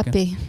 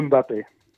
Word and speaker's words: Mbappé: Want ja, een van de sterren van dit EK e is natuurlijk hoe zeg je dat? Mbappé? Of mag je die M niet uitspreken? – Mbappé. Uh, Mbappé.